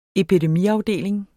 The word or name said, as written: epidemiafdeling